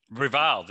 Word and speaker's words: reviled: They are revealed by law, reveled. Is that reveled is pronounced correct? – Reviled. They're reviled. – It is reviled.